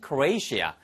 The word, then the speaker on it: Croatia: 지금 살고 있으니까 Tom's living in Croatia. 이것만 주의하세요. 크로아티아를 영어식 발음은 미국식 발음에서는 Croatia. 이렇게 발음하시면 되겠어요.